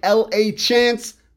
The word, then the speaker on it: LaChance: Facebook is my name, Brandon LaChance. It looks like L-A Chance, pronounced LaChance.